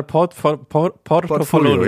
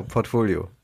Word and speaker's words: Portfolio: Ah, ich glaube, es war Portofolio. – Ja, ich habe hier jetzt auch genau Portfolio. Ja, sehr gut.